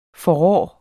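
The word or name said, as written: FORRÅ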